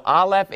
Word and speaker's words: Aleph: So Aleph is just a silent letter.